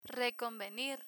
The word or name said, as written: reconvenir